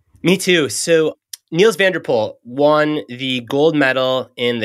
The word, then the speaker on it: Nils van der Poel: And you use a great example of this speed skater, Nils van der Poel. Is that how you say his name? – Yeah, Nils van der Poel, you got that right.